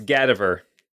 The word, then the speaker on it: Gadaver: It's Gadaver. So take cadaver and change the C to a G and then change the emphasis to the first syllable, Gadaver, instead of cadaver.